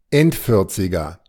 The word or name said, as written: Endvierziger